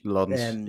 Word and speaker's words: Lens: Lens, yeah. – Pronunciation. Oh, Lens. What did I say? Lens. – I think it's Lens, yeah.